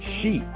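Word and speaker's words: shi (し): But the number 4 can also be pronounced in Japanese as shi, S-H-I.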